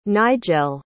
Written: nijel

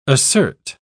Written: assert

